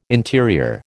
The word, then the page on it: interior: American English